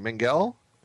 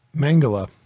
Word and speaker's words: Mengele: Back at the surf Nazi camp, Mengel, how do we say his name? Mengel? – Mengele. – Mengele.